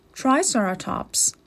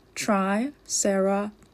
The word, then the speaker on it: Triceratops: How do you say it? Triceratops. Say Triceratops. If these charge, we'll have to get out of here.